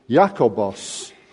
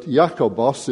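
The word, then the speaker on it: Iakobos: In fact, if you look in the Greek original, you see that the name is Jakobos, which doesn't sound much like our English James. But Jakobos is the Greek equivalent of the Hebrew Yaakov, which we pronounce Jacob.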